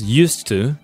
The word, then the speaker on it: used to: First of all, it's written with D at the end, so U-S-E-D, and it's pronounced as used to, and it refers to the past, things that you did in the past, but you don't do them anymore in the present.